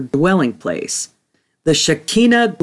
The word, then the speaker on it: Shekinah: Let's begin with an understanding of the Shekinah glory, or correctly pronounced Shekinah glory.